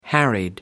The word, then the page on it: harried: British English